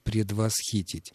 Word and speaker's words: предвосхитить: В этом глаголе произошло все-таки перемещение ударения с корня на суффикс, но это очень широко употребительный глагол, в отличие от предвосхитить. И поскольку он редко употребляется, ударение в нем осталось на корне, то есть там, где оно изначально и было.